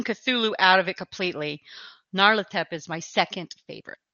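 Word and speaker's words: Nyarlathotep: Narlatep, which I don't know if I'm saying it right. – That sounds right because he's more Egyptian. – Yeah. I do like Narlatep.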